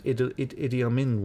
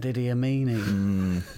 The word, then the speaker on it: Idi Amin: I pronounced Idi Amin wrong. – I said Idi Amini.